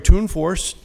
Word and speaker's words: Tunforss: My name is Larry Tunforss, T-U-N-F-O-R-S-S.